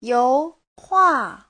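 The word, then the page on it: youhua: you2hua4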